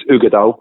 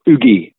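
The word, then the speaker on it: Uigeadail: Then there's Ugadal. And it's spelled weird, so it's U-I-G-E-A-D-A-I-L, so Ugadal, but it's actually Ugadal, so it's just pronounced Ugadal, or Ugi, we all just call it Ugi for short, and then you have Corrie of Wrekin.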